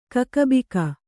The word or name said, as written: ಕಕ್ಕುಬಿಕ್ಕು